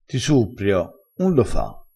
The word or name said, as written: suppria